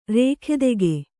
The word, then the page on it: ರೇಖೆದೆಗೆ: Kannada